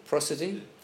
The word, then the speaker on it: prosody: Prosody.